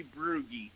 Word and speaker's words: Brugge: I will say Brugge. – Very, very close. Very close. It's actually Brugge.